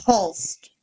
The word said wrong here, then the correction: Holzer: Holzer. – H-O-L-Z-E-R.